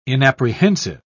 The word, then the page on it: inapprehensive: British English